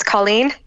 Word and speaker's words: Cailín: It's actually an Irish kind of surname, so it's Cailín.